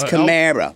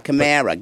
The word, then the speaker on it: Kamara: It's Camara, like in Chalmette, bro. Camara, get in your Camara.